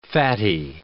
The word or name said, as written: fatty